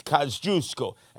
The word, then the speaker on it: Kosciuszko: And remember, he looked down in the streets right outside the Mickey D's at Kosciuszko, not Kosciuszko Street, you gavons, it's Kosciuszko. That's the way you pronounce it in Polish.